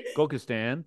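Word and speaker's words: Gullkistan: She has been awarded residences at the Jack Kerouac House, the Disquiet International Program in Portugal, and the Gulskitan. Did I pronounce that correctly? – Gulkistan, thank you.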